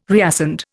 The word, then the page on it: reascend: Indian